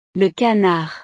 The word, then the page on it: le canard: frances